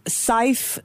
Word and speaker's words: Syfe: Dhruv Arora is founder and CEO of Syfe. It rhymes with life.